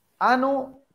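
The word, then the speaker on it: Anno: Hora de hablar de Ano, porque así se pronuncia, Ano 1696, eh, 1696 de Insomnium.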